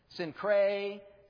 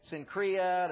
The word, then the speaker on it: Cenchrea: I had to work on that pronunciation. Cenchrea, Cenchrea. There was different ways that I saw to pronounce it, but they all agreed that first C is a soft C. It's the letter S. And then Cray or Crea or Cree, Cenchrea.